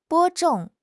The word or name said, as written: 播种